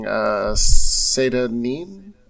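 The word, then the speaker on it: Seyda Neen: So I would say that it translates Morrowind as a region fairly well, like seeing, Seda Neen, I believe is how you say it.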